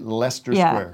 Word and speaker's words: Leicester: Our gentlemen, the Sleaford Mods, who are coming up later, confirmed that we shall be pronouncing it Leicester Square.